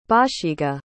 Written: bashiga

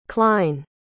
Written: Kline

